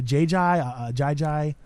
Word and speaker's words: Ajayi: I never knew how to pronounce his last name. Is it a Jay Ajayi? There you go.